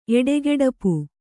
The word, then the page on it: ಎಡೆಗೆಡಪು: Kannada